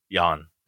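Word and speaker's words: Jann: Meanwhile, is it Jan? Jan? – Jan. – Jan, okay, I just want to make sure I was pronouncing it right.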